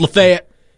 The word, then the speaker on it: Lafayette: It's Lafayette.